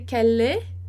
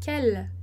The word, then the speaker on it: quelle: A gente não vai dizer quelle, porque a gente não vai pronunciar esse e no final, então a gente vai dizer quelle, tá? Esse e no final ele morre e a gente marca ali quelle no L, tá?